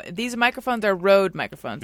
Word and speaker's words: Rode: Rode is an Australian microphone company.